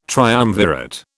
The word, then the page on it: triumvirate: British English